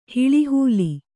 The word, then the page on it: ಹಿಳಿಹೂಲಿ: hiḷi hūli